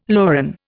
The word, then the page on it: loran: Indian